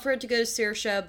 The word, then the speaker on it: Saoirse: Saoirse Ronan. – Thank you. From Little Women. – Rhymes with inertia.